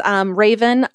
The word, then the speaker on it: Rayvan: It is pronounced like the bird, but it is Raven, R-A-Y-V-A-N.